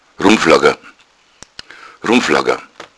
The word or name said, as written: rummflagga